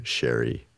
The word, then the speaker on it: Sheri: So I want to welcome to the show, Sheri.